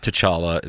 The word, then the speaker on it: T'Challa: 12 years old, and he wrote, I love T'Challa.